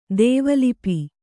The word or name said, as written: ದ್ವಿಲಿಪಿ